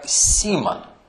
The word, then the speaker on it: Симон: Из Писания мы знаем, что в начале своего пути с Господом его имя было Шимон, или в греческом произносится как Симон, Семен.